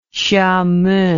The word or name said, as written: xiamen